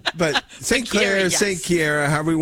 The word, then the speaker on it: Chiara: We are celebrating a wonderful saint today, a saint that we refer to as Claire, but that's not how her parents pronounced her name, right? Saint Chiara. – Chiara, that's Italian.